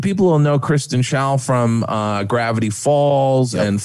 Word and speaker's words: Schaal: Kristen Skull, I can't pronounce her last name. Scholl? – I've got it. Stand by. It's Kristen Schaal as number Schaal.